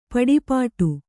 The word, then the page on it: ಪಡಿಪಾಟು: paḍi pāṭu; kannada